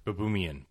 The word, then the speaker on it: Baboumian: But then as far as, you know, I mean, the world's strongest man right now, Patrick Baboumian, I think I got it. – I think you did too. – Baboomian. Baboomian. – Yes. – It's that second Baboomian. – Syllable emphasis.